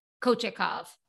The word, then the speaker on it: Kochetkov: Kochetkov? – Kochetkov.